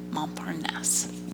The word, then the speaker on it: Montparnasse: Today we're talking about Season 1, Episode 7, Murder in Montparnasse. And I said it wrong. Montparnasse. – Montparnasse.